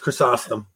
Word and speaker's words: Chrysostom: You pronounced it wrong. It's Chrysostom.